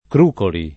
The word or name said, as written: Crucoli